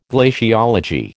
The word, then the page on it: glaciology: American English